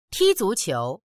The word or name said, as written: ti1zu2qiu2